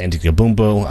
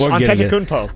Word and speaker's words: Antetokounmpo: Giannis Antetokounmpo. Hopefully I can pronounce that properly. – That was close. Antetokounmpo.